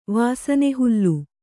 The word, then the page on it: ವಾಸನೆಹುಲ್ಲು: vāsane hullu